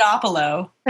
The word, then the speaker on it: Podopolo: It's Podopolo.